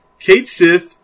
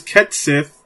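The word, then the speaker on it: Cait Sith: Kate Sith seems to be pronounced as Ket Sith, which this is a crime.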